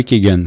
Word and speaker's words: Keoghan: Je pense que c'est Barry Keoghan. Je ne sais pas si je le prononce comme il faut, par contre, mais euh, ce, ce, jeune acteur-là était époustouflant. Puis en plus, oui, c'est bien ça.